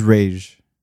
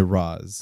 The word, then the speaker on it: Ra's: Well, the correct is Ra's. It was Batman Begins that changed it to Ra's.